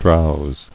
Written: Drowsed

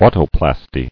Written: Autoplasty

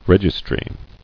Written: Registry

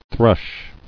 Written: thrush

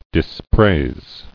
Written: Dispraised

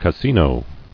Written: Cassino

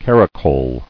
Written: Caracoled

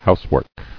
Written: Housework

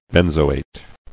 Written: Benzoate

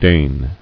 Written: Dane

